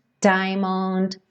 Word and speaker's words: diamond: Nessa canção, a gente tem várias vezes a letra T e a letra D, que são consoantes oclusivas, a gente consegue vê-las pintadinhas de vermelho, precedidas de letra N. Can't, diamond, friend. E quando isso acontece, é possível que essas consoantes oclusivas não sejam pronunciadas, praticamente não apareçam.